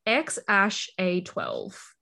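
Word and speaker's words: X Æ A-12: It's, I believe he explained that it's like X-A-E something. He said okay. Part of it is pronounced Ash. – Ash. Yeah. Yes. I'm just reading that now. X-Ash-A-12. That's how you pronounce it.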